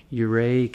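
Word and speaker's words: Ouray: But Uray, California, or Colorado, and it's spelled U-R-A-Y.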